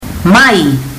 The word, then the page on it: mai: fiji